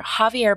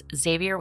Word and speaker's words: Javier: In March of this year, Javier Becerra, whose name I mispronounced as Xavier with an X in an early episode, I regret the error, was confirmed to be President Joe Biden's Secretary of Health and Human Services.